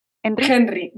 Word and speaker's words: Henry: Henry. yo lo, yo lo españolizo.